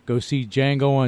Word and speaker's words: Django: Django Unchained, and that D is silent.